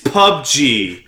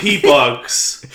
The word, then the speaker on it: PUBG: I've been missaying it. It's PUBG. It's not P-Bugs.